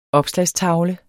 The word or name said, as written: opslagstavle